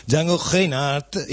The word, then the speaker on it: Django Reinhardt: Entonces, el famoso artista francés que hacía estas canciones se llamaba, como muchos habrán, ya saben, Django Reinhardt. Intento pronunciarlo a la manera francesa sin escupir sobre la mesa de sonido del estudio 1 de Radio Contrabanda, que no me permitiría nunca hacer algo así.